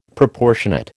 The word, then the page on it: Proportionate: American English